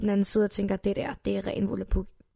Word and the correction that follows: volapyk: Volapyk. Så meget Volapyk, at du engang udtaler Volapyk.